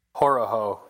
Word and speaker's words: Horoho: And I just, the fact that you're able to find a guy like Brandon, how do you pronounce his last name? Hororo? – Horoho, yeah. – Horoho.